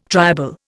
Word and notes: triable: Indian

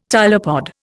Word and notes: tylopod: Indian

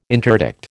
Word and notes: interdict: American English

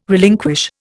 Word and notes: relinquish: Indian

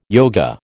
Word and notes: yoga: American English

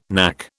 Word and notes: knack: British English

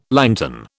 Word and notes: lantern: British English

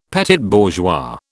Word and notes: petit bourgeois: British English

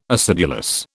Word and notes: acidulous: British English

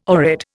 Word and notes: oread: Indian

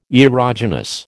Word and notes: erogenous: American English

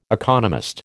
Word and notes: economist: American English

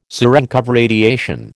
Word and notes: Cerenkov radiation: American English

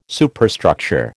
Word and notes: Superstructure: American English

